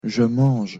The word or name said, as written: je mange